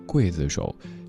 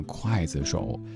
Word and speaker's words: 刽子手: 刘德华的这首歌曲也算是在华语歌坛当中非常特别的一个案例，就是由于一个字，歌手本人甚至道过歉，告诉大家我当年确实有唱错了，这个字应该念刽子手。之后的所有的现场演唱，刘天王都改成了正确的读音。可是当时这首歌太红，所以事到如今，也有好多朋友都以为就念筷子手。